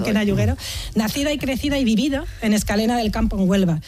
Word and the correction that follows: Escacena: Escacena. Mira que te lo he dicho mal varias veces, por ahí está.